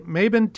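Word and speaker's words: Mabon: Maben Teenie Hodges.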